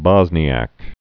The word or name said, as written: Bosniak